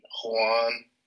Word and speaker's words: Joan: I've been reading a lot of the works of Joan Juan Cornella, but it's spelled Joan.